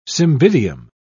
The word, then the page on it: cymbidium: British English